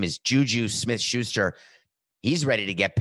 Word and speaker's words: JuJu: His name is J-U-J-U. Coco, how do you say his name? I was going to say it's Juju, but that seems not nice how to say the name. But that's how to say it. Okay. His name is Juju.